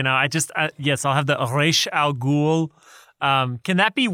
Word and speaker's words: Ra's al Ghul: Ra's.